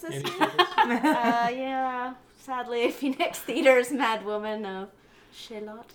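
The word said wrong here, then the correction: Chaillot: is that how you say it? – Shio, that's right.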